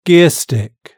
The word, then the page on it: gearstick: British English